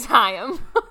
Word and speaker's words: Haim: Just so you all know, we just looked this up and confirmed that it is Haim. – We confirmed that her last name is pronounced Haim, but maybe the band is pronounced Haim.